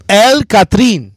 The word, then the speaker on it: El Catrín: El Catrín. – Did I say that right? – Yeah, bro. You said that.